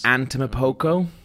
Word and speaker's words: Antetokounmpo: Giannis Antetokounmpo. – Antetokounmpo.